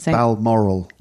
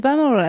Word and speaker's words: Balmoral: Balmoral. – Ah, it's Balmoral.